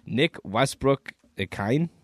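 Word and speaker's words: Nick Westbrook-Ikhine: And then your receiving leader for Tennessee, you got Nick Westbrook Ikhine. Is that how you say his name? – Yes.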